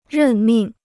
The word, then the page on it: 任命: rèn mìng